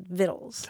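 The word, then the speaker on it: victuals: vittles. He said she was born in 1898 and grew up in Glasgow, Scotland. – Okay. – Yeah, it's spelled like vitals, but it's pronounced vittles.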